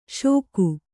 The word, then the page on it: ಷೋಕು: Kannada